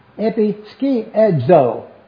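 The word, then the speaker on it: Epi, ski, edzo (ἐπισκιάζω): If you'll notice, it is the word E-P-I-S-K-I-A-Z-O. Interesting word also. Let's see if I can pronounce it for you. It's pronounced E-P-I, epi, ski, S-K-E-E, A-D, so. Epi, ski, edzo.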